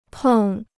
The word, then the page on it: 碰: pèng